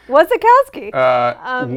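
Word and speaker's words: Wasikowska: You got to interview him and Jessica Chastain and Mia, our friend Silas says the way that he remembers how to say her name is he thinks of a Russian who's never seen a cow. – That's actually awesome advice. – Wasikowski.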